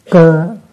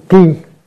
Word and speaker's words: cơ: Cơ cái chữ này nguyên đọc là chữ ki, nhưng mà mình quen đọc là chữ cơ.